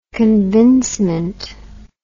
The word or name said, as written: convincement